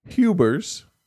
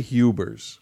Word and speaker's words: Huibers: His name's spelled Huibers. I'm going to say Hubers. – Hubers. – Just to say that. – It rhymes with goobers.